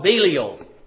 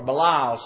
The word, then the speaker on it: Belial: He's also called Belial, or Belial, some pronounce it, 2 Corinthians 6 and verse 15.